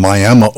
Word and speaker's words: Miami: Before Shania, we had Keith Anderson on and Picking Wildflowers, a young man from Miami, Oklahoma. And yes, that's how you say it. There's Miami is in Florida. Miami is in Oklahoma, spelled the same way.